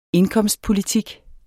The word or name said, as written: indkomstpolitik